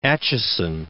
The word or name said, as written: Acheson